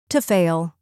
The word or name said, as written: to fail